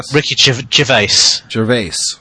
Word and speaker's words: Gervais: Is that how you say his name, Gervais? – Ricky Gervais.